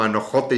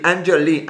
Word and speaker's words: Anne Jolie: Y yo voy a intentarlo una vez más en contra de todas mis más profundas convicciones a no Anne Jolie, eh, se, leía como Anojote y dije, eso suena raro. Anne Jolie, Anjoli, Anjoli sería la pronunciación, pronunciación correcta, se acaba de unir aquí a la transmisión.